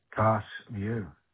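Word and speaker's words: Carse View: Cast View. – You might have to spell that for me, sorry. – C-A-R-S-E. – Cast View. – Yeah, V-I-E-W, Cast View.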